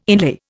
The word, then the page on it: inlay: Indian